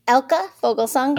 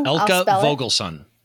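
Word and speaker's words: Elke Vogelsang: Elka Vogelsong. – Elka Vogelsong.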